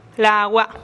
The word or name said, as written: lagua